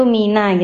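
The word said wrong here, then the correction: minna (مِنَّا): gitu ya. Jadi tasdidnya hilang ya. Dan itu harus didengungkan dulu nggih. Minna bukan mina tapi minna adabun alim.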